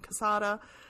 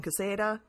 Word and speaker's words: Quesada: And he said it was a big old tomato tomato situation. I could call him Quesada. He'd call him Quesada.